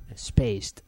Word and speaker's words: Spaced: Vamos a recomendar, yo por mi parte, voy a recomendar una serie que se llama Spaces. Spaces. – ¿Cómo se pronuncia? – Spaced. – Joder, es que como se nota en los angloparlantes. – Con D al final.